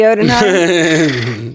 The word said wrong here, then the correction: Jotunheim: I would always think that Odenheim would be spelled with an O. – It's Jotunheim.